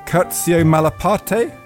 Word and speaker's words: Curzio Malaparte: Ian Baruma has written about the Italian fascist and fabulist Curzio Malaparte. How's that, Thea? What's the pronunciation? – Lovely.